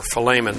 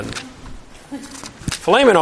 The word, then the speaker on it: Philemon: Remember, it's right after Philemon. Philemon always sounds like a steak to me.